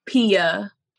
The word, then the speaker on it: Pia: Instagram is Pia, which is the stage name, nickname I go by, which is P-I-A, then underscore Irene, I-R-E-N-E, underscore the artist.